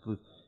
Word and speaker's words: Bluth: Like, Bluth, I actually looked it up, it's pronounced Bluth.